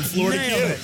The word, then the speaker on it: Fleur de Queue: And this is super special because I've managed to track down Team Fleur de Queue, and I probably just butchered that pronunciation. – No, yeah, you did a fine job. Fleur de Queue, like Fleur de Lis, as in the saints of the flower, but Fleur de Queue.